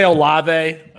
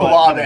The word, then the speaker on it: Olave: Chris Olave. Is that how his name is pronounced? – I think we say Olave. – But, Olave.